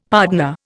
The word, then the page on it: pardner: Indian